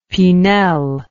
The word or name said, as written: Pinel